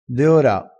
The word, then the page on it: deora: dialettale